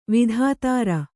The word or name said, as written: ವಿಧುಧರ